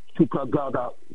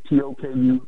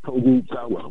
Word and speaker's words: Tokugawa: Their attempts to impose themselves on China and Japan were quickly defeated by the Chinese Coast Guard fleets, incurred by Tokugawa, Shogun of Japan. I'm not saying that right. Tokugawa. Tokugawa. Yes, that is right.